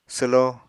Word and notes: swlo: apatani

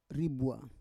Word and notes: ribua: Roon

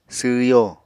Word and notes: sww yo: apatani

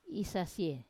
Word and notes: isasie: wamesa

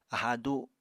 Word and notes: aháa du: Apatani

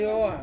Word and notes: oa: Ansus

